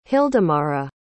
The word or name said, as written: hildemara